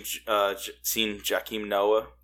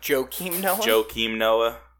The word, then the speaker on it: Joakim Noah: I seen Joakim Noah. He was there. It didn't help me at all. – Joakim Noah.